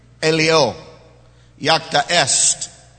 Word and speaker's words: Alea iacta est: And it's pronounced Alea Iacta Est.